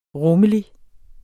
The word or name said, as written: rummelig